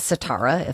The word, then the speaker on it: Sitara: And he, this woman, we come to know her name is Sitara, if I'm saying that properly.